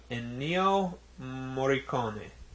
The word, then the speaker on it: Ennio Morricone: But for this week's Nook of Darkness, we highlight the long career of Ennio Morricone. – Very nice. – Did I mispronounce that terribly? – No.